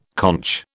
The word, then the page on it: conch: British English